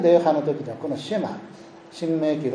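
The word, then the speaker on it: シェ マ (שמע): 聞けという言葉はヘブライ語ではシェマというふうに言います。シェマイスラエルというふうに発音します。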